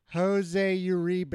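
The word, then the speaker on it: José Uribe: It was like hose, like garden hose, capital A, Y-O-U-R-E-E-B-A-Y. Jose Uribe.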